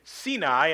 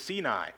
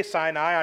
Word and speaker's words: Sinai: And you may have noticed over the last couple of weeks when I have referenced this mountain, I have pronounced it Sinai, and most of you have never heard it pronounced that way. I am a stickler for trying to be biblical in my pronunciations, and that is the actual pronunciation. The Hebrew pronunciation is Sinai. And so it's okay if you say Sinai.